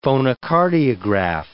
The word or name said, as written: phonocardiograph